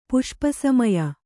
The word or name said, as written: ಪುಷ್ಪಸಮಯ